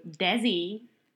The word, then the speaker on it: Desi: I think it would be called Life of a Global Desi Yes, uh, and Desi, not as in designated driver, but it's pronounced they see.